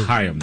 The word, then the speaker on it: Chaim: It's pronounced Chaim, though, I think.